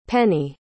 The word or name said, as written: penney